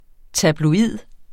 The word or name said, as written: tabloid